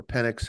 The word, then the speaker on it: Penix: Penix, how do you pronounce it? – Penix.